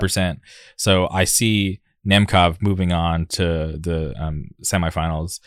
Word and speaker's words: Nemkov: Since we're on that side of the bracket, Vadim Nemkov, am I pronouncing it? Nemkov.